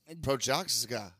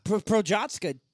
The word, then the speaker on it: Procházka: You want to pronounce that for me, dude? – I think you got it. Procházka. – Procházka. Jiří Procházka. – Good job.